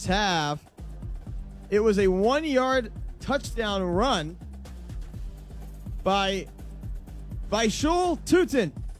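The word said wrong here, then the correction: Bhayshul Tuten: Did I say it right? – Basil Tootin, I believe, is the correct pronunciation.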